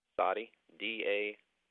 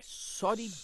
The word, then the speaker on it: Soddy Daisy: Soddy Daisy? – How do you spell that? – S-O-D-D-Y. – Soddy. – Soddy. D-A-I-S-Y. – Soddy Daisy.